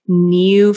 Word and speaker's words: NEOU: I think it's called New Fitness. It's NEOU.